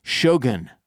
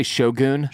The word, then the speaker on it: Shogun: Next up, we'll be covering Shogun. That's how it's pronounced. – Shogun? – I say Shogun. – Shogun. – But that's wrong. That's just white people.